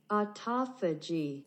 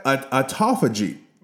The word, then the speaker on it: autophagy: Autophagy. – You heard that? Autophagy.